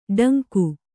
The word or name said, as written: ಡಂಕು